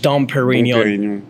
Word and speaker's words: Dom Pérignon: Dom Pérignon.